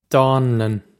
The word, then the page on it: dánlann: dawn-lun; Irish